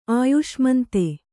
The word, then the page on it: ಅಯ್ಸಾಮಂತ: aysāmanta